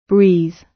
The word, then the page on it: breathe: British English